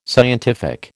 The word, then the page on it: scientific: American English